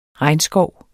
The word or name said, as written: regnskov